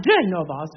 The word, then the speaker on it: Genova: À la spéciale, l'annonce disait bien Genova, ce qui est la façon italienne de désigner la ville de Gênes, avec l'accent sur la première syllabe, Genova. J'ai toujours beaucoup insisté auprès des chanteurs pour qu'ils respectent les accents toniques. Genova, et non Genova, qui évidemment, en français, donnerait Genève. En plus, les Suisses disent Schneve, ce qui ne simplifie pas les choses. Donc, nous serions à Genève, avec un V après l'accent tonique, et non à Gênes, avec un N.